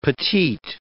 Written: petite

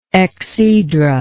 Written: exhedra

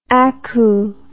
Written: aku

